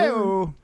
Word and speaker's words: Ayo: A-Y-O River? – A-Y-O space River. – A-O River, yeah. A-O. A-O River. – A-O.